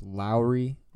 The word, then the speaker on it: Lauri: So I did I did hear it's it's pronounced like Kyle Lowry's last name, so it's Lauri Markkanen.